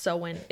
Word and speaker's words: Samhain: It's spelled really weird and pronounced really weird, and you're just going to pretend you know what I'm talking about here. – We'll write it down below. – Yeah. – But it's pronounced Samhain.